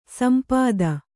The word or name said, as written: ಸಂಪತ್ತಿ